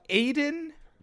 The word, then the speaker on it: Aydin: Um, uh, Aydin Paladin, A Y D I N, Paladin.